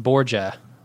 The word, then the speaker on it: Borgia: And she says that show called Borgia is pronounced Borgia, like Jamaican, but with a Z in it.